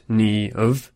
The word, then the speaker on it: Niamh: Niamh, pronounced Ni-of, means brightness, radiance.